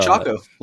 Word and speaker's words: Choco: Is it Choco Pro or Choco Bro? – I think Choco, like chocolate.